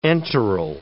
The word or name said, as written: enteral